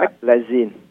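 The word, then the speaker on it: Meclizine: M-M-E-C M-E-C L-I ngắn. Rồi chữ Z. Z là chữ Zu á, Z á. – Dạ, biết rồi. – Xong I, N-E đọc là Maclazine.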